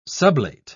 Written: sublate

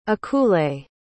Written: akule

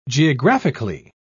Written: geographically

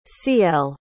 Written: cielle

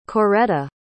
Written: Coretta

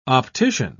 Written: optician

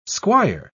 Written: squire

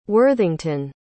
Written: worthington

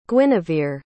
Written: guinivere

